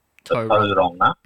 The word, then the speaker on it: Tauranga: So I guess if you think of toe as in the toe on your foot, Tauranga, that would be probably be the way if you're trying to, I guess in very layman's terms, break down how it should be pronounced. – Okay. – You've got to probably T-O-E, and then think of the word wrong, right versus wrong. Toe-ronga.